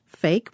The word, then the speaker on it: PHEIC: It's pronounced fake, which, of course, is confusing.